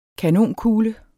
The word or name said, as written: kanonkugle